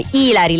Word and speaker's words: ilari: Oppure ci sono anche frasi ilari, ilari, perché è come Ilari Blasi, ilari, l'accento è sulla I, tipo vai a casa e sgrida il tuo umano, ok?